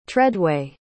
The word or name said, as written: treadway